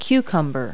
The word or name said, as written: cucumber